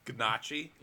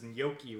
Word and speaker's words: gnocchi: I just remember you telling me about it, and it's like, Gnocchi? – Yeah, no, he pronounces gnocchi wrong. He goes, gnocchi. And I'm like, no, that's not how to pronounce it. It's gnocchi.